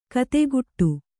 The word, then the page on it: ಕುದಿಗುಟ್ಟು: kannada; kudiguṭṭu